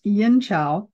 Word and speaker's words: Yin Qiao: Now, the formula that goes with Gan Mao Ling sometimes is Yin Qiao, and it can be spelled Q-I-A-O or C-H-I-A-O. Sometimes Qi is spelled Q-I and sometimes Qi is C-H-I, but it's Yin Qiao.